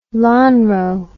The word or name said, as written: Lonrho